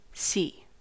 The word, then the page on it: see: American English